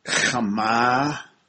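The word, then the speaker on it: chama (חמה): Also the word chama, you have to pronounce it deep, it's a guttural.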